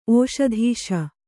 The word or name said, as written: ಓಷಧೀಶ